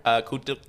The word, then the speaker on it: Kotierk: Am I saying that right? – Kotierk, yeah.